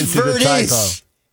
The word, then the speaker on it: Verdes: And it's Verdes. It's not Verde. – That part was probably just me getting stupid. Rancho Palo Verde. – He had Palos Verdes.